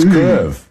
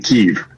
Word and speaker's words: Kyiv: Now, the Russian pronunciation is Kyiv. The Ukrainian closest approximation that we can do as non-Slavic speakers is Kyiv. So the Y ends at the first syllable.